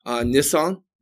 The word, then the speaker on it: Nisan: That there's a Lunar New Year that happens in again, forgive me if I mispronounce this, Nisan. – Mm-hmm. – Is that how you pronounce that? – Yeah.